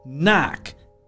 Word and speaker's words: knack: It's knack, K N A C K. The first K is silent.